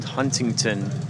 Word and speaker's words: Huntington: Even though it's spelled Huntington, folks like to call it Huntington. They just bowl right over the T's.